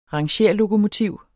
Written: rangere

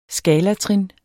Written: skalatrin

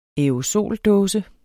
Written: aerosoldåse

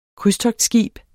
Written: krydstogtskib

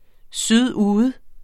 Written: sydude

